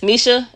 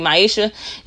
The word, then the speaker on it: Maisha: So if you go to Creativo on YouTube, you will see, they spelled my name wrong. But once you click on it, it says Misha. It's supposed to be Maisha.